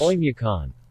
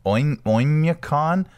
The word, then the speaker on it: Oymyakon: But how do you pronounce this? – Oymyakon. – Oymyakon. All right. That's fine. That's. We'll just go with that.